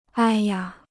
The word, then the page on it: 哎呀: āi yā